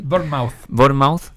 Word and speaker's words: Bournemouth: porque no solo ahora que jugó con el Bournemouth, creo que se dice así, Bournemouth,